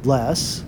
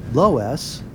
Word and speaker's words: loess: It's spelled L-O-E-S-S, and it's alternately pronounced less, low-s, or more like the German root lus.